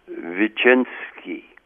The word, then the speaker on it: Wyczynski: I was in the Polish ghetto, and I'm going to pronounce his name the way it would be pronounced in Polish, Mr. Wyczynski.